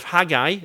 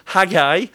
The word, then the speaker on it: Haggai: And yet people in the book of Haggai, is how I'd pronounce it, Haggai,